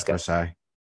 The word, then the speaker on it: Versailles: Great War officially ended on June 28th in 1919 with a peace treaty, the Treaty of Versailles. Even though I might be mispronouncing that, I'm pretty sure it's a French name. So, no, I think it's Versailles.